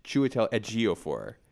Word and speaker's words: Chiwetel Ejiofor: I had Chiwetel Ejiofor. It's Chiwetel Ejiofor.